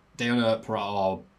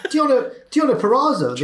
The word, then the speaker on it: Deonna Purrazzo: And he'd need it because Nia Jax is back and she squashes Deonna Purrazzo, butchered that name, her opponent to celebrate. – Deonna Purrazzo, the right.